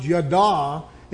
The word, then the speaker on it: yada: That original Hebrew word there that we read in the Old Testament, it's a word that I think it's pronounced yada, and it really has to do with family, a relationship that you would have with family.